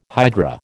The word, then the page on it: hydra: American English